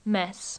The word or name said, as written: mess